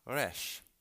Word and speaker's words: Resh: And it's pronounced Resh, just like the letter.